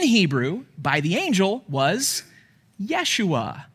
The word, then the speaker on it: Yeshua: In the original Hebrew, the name the angel said was Yeshua, because the Jews, they didn't have that hard J sound, so his name is Yeshua.